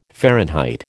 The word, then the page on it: Fahrenheit: American English